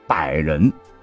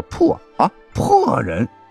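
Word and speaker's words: 柏人: 结果呢，刘邦在经过途中百人县，晚上要下榻此地唯一一座高级宾馆时，这刘邦就忽然问侍从说：“ 此地何名？” 答百人，柏树的那个柏，可是由于刘邦啊，他是徐州人，口音重啊，就念成了破啊破人，逼迫人的地方，认为大大不吉，就临时赶路去另外的地方入住，使得原本成功率极高的刺杀功亏一篑。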